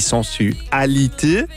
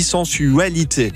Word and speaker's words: sensualité: Elle le prononce de deux façons différentes. Hein, son titre, elle dit « sensualité » et puis à d'autres moments, elle dit « sensualité ».